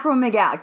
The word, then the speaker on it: acromegalic: You end up with acromegalic gigantism, acromelia, acromallergy. – Roche syndrome. – I'm saying this wrong. Acromegaly. – I don't know. – All right. – Acromegalic? – Yeah, acromegalic. Thank you.